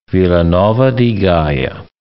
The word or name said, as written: Vila Nova de Gaia